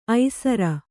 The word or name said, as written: ಐಸೂರಿ